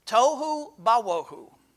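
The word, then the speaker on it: tohu bawohu (תֹהוּ וָבֹהוּ): The Hebrew word here for void is pronounced tohu bawohu.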